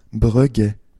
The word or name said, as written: Breguet